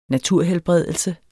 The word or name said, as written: naturhelbredelse